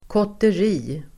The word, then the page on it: kotteri: [kåter'i:]